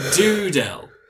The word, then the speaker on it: Dudel: I've been playing Doodle. Now, it's spelled D-U-D-E-L.